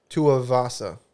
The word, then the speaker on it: Tuivasa: Tuivasa.